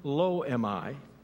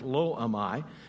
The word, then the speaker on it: Lo-Ammi: So the third son is born, and it says, name him Lo-Ammi. That's how you say it. That's absolutely correct. Lo-Ammi. Name him Lo-Ammi.